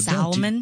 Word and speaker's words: salmon: One of the ones people mispronounce a lot that I always notice is salmon, because they'll say salmon. – Yes. No, salmon. Don't do that. – And I'm like, that L is silent.